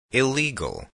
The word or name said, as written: illegal